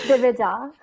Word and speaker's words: Divija: Can you pronounce your name the proper way? – Wow, I'm nervous now to pronounce my own name. It's DIVIJA.